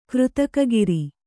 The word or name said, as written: ಕೃತಕಗಿರಿ